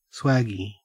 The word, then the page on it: swaggie: Australian English